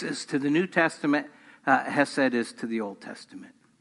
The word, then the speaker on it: hesed: It's called hesed. We would, in English, spell it H-E-S-E-D.